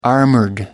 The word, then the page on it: armored: American English